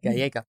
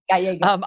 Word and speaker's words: Gallego: You, as I understand it, and obviously since I didn't know how to pronounce Gallego, it's Gallego. – Gallego.